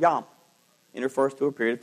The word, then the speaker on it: yom: The Hebrew word for day is the word yom, Y-O-M, or it's pronounced that way. I don't know how it's spelled, but yom.